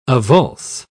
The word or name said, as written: avulse